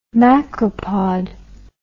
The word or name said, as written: macropod